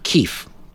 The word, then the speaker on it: Kyiv: Their runner-ups were Kyiv. – Yes. – Actually, we would pronounce it Kyiv, which is K-Y-I-V.